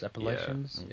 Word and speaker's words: Appalachians: do you know how to pronounce that? – No. – Appalachians. – Appalachians, yeah.